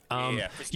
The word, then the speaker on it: Prismari: Prismari.